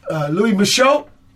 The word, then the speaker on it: Michot: I got Louis Michot.